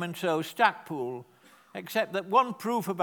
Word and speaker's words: Stackpole: So on a similar principle, I've called the house Stackpole, but it's pronounced Stackpool, it's spelt Stackpole.